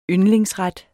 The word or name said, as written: Yndlingsret